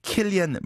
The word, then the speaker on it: Cillian: 예를 들어서 Saoirse Ronan, S-A-O-I-R-S-E, R-O-N-A-N. 이 왜 Saoirse Ronan인지. 그다음에 Cillian Murphy, C-I-L-L-I-A-N, Murphy가 왜 Cillian Murphy인지, Cillian이 아니고 말이죠.